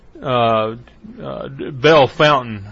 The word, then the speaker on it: Bellefontaine: Well, we are in, well, the locals call it Belle Fountain. It's spelled Bellefontaine, so I don't know what it is.